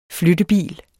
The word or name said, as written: flyttebil